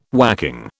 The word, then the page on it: whacking: British English